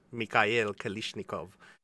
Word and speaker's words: Kalashnikov: Yeah, I had Jill actually mention me how you pronounce the name in Cyrillic, and it's basically the same thing as saying it in English. So I tried my best to make sure it was accurate, but she said, yeah, it's just Mikhail Kalashnikov.